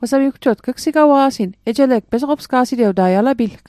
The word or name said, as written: pesoqopsga'sit